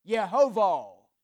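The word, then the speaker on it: Yehovah (יהוה): That word Lord in the Hebrew transliterates and is pronounced Yehovah. And in the process of transliteration, we either come up with Jehovah or Yahweh.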